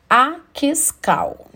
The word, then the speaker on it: Akiskal: É os quatro padrões de temperamento de Akiskal, né? A pronúncia aí do pesquisador é A-K-I-S-K-A-L né? Então, fala aí sobre o transtorno bipolar e temperamento, eu acho que é importante.